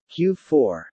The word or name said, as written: Q4